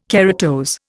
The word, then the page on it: keratose: Indian